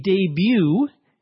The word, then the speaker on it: debut: A debut, D-E-B-U-T, notice we don't pronounce the T, is one's first formal appearance into the world of high society as a young woman.